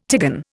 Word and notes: tigon: Indian

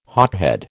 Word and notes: hothead: American English

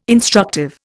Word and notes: instructive: Indian

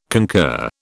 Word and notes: concur: British English

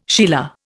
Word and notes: sheila: Indian

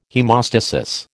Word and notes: haemostasis: American English

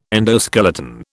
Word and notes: endoskeleton: British English

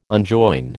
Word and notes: unjoin: American English

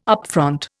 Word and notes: upfront: Indian

